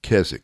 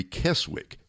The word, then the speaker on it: Keswick: And so one of the conference centers associated with that is in New Jersey, and it's called America's Keswick. It's spelled as, if you look at the word, Tim, it sounds like it should be Keswick, but it's Keswick.